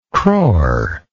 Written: crore

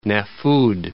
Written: Nafud

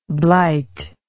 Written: blite

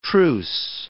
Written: truce

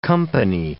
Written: company

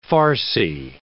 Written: farcy

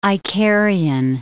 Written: Icarian

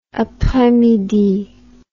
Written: apres midi

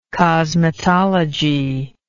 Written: cosmetological

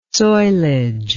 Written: soilage

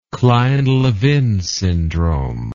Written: kleine levin syndrome

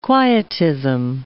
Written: quietism

